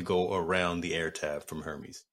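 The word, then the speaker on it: Hermès: Outside of and apparently the H is silent. Hermes. Gotta talk. Hermes. See, I roll the R's there.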